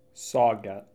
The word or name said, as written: Sauget